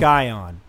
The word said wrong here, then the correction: Scion: S-C-I-O-N. Is that a car? – It's a car, yeah. – Well, there's a dealership. – It's a Scion. – Scion, it's pronounced. My bad.